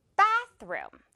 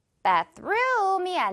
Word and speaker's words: bathroom: 그러니까 bathroom이 아니라 bathroom. 이렇게 발음을 해줘야만 영어 원어민들은 이걸 하나의 단어로 인식해요. Bathroom 이렇게요. Bath 부분에 강하게 강세 주는 거예요.